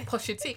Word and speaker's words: Pusha T: Pusha T. Come on, because it's Push.